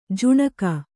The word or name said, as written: ಜುಣುಗು